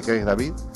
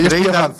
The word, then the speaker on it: Craig David: Y nos ha propuesto este Seven Days de, de Craig David. Y con él te, te despedimos. Esa pronunciación, eh. – cuidado. Es, que Craig, David.